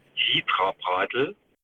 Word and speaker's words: Hitra-Bradl: Ganz besonders lustig finde ich das Wort für das erzgebirgische Tablett, das Hitra-Bradl. So wird es ausgesprochen.